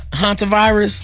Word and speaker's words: hantavirus: It's hantavirus.